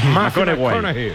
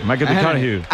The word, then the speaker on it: McConaughey: Que hay que ir corrigiendo la pronunciación. – McConaughey. McConaughey.